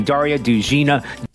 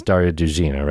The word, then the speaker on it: Daria Dugina: Well, speaking of Daria, this is how they pronounced her name. Daria dead at the scene. Daria Dugina. – So first one, you heard that? First one is Daria Dugina, right? Like in Borat with the Vagine.